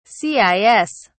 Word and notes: cis: British English

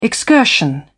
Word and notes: excursion: British English

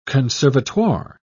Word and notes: conservatoire: British English

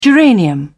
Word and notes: geranium: British English

